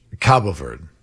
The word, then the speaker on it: Cabo Verde: I'm seeing, by the way, it's pronounced both ways. It's pronounced Cape Verde or Cape Verde, but the Portuguese pronunciation is Cabo Verde.